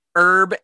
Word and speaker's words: herb: Herb and its derivatives, such as herbaceous, herbal, and herbicide, and herbivore, are pronounced with the H. – I'm so confused by what words you said because you didn't pronounce the H. – In American English, herb and herbal are more often pronounced with the without the H.